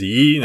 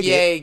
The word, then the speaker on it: Ye: Pronounced Ye Crowder. – Ye? It's Ye now. – That's a weird way to say fucking bigot. – I think it's pronounced Ye, guys, but.